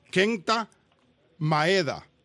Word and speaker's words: Kenta Maeda: El pitcher japonés, y lo quiero pronunciar bien, porque sin querer lo he pronunciado mal, Kenta Maeda. ¿Ok? Lo pronuncié bien esta vez.